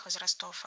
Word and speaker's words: возрастов: А я отвечу, не так может быть поставлено ударение во множественном числе. И вот первые примеры. – Он может оказаться не совсем понятен, наверное, публике всех возрастов.